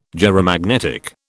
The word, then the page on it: gyromagnetic: British English